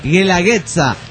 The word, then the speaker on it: Guelaguetza: Supongo que todos los oaxacos nos encabronamos cuando dijiste Gualagüetza. Dice, no se pronuncia Gualagüetza, sino Guelaguetza. Dice, es, güey. Dice, como guerra.